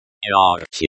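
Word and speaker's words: yarchi (ярче): Yarchi. – Pronounced, yarchi, yar, as in, yard, and, chi, as in, check, with a softer, ch, sound, means, brighter, and is the comparative adjective of, bright, yarkaikatkoye.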